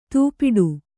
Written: ದುಪಟ

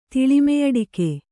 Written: ತಿಳಿಮೆಯಡಿಕೆ

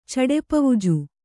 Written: ಚಡೆಪವುಜು

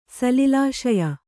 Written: ಸಲಿಲಾಶಯ

